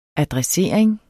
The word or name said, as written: adressering